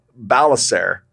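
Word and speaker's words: Ballecer: But I came across this story about Father Robert Balacer. I hope I'm saying his name right. It's B-A-L-L-E-C-E-R. – He is.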